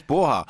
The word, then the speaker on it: porra: Och de normala tillmälena på portugisiska, eh, carajo och poa, poa säger han ju men han säger porra och det, det stavas ju porra men det uttalas poha.